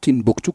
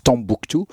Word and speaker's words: Timbuktu: Et Timbukti par rapport à la ville où il habitait qui s'appelle Timbuktu, même si aujourd'hui on la prononce Tambuktu. C'est Timbuktu comme Hmm.